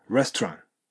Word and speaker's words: restaurant: thinking of, そして restaurant. これ T を発音しないことが多いです。そうすると、 restaurant.